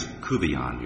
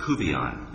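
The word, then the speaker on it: Couvillon: My name is Kuvion, Your Honor. – What's that? – Kuvion. – How do you spell that? – C-O-U-V Victor I-L-L.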